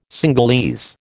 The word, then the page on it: Cingalese: American English